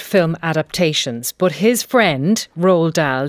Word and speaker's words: Roald: Well, I think most people say Roald Dahl, and I do as well, but yeah, it's Roald, it's a Norwegian name.